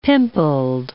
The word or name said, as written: pimpled